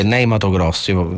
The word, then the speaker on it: Ney Matogrosso: Che è Ney Matogrosso. Faccio la pronuncia così italiana. – Hai detto benissimo.